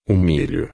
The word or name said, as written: milho